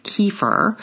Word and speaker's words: kefir: Both yogurt and kefir, which you'll also hear pronounced kefir, are cultured milks that thicken when beneficial bacteria consume the sugars in the milk and acidulate it.